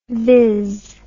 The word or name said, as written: viz